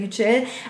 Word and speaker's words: Yücel: Nicht Yücel, sondern Yücel.